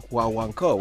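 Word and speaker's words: Guaguancó: The original's by this Mexican band called Los Huahuanco, and they spell it W-A-W-A-N-C-O, which is a play off the Cuban, Afro-Cuban rumba style called Huahuanco, which is G-U-A-G-U-A-N-C-O.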